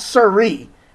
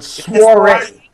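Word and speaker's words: soirée: The word soiree, which is how it's pronounced. Not siree, but soiree.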